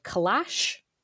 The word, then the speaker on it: calash: Can you explain for us what, I don't even know if I'm pronouncing this correctly, calash? – Yep.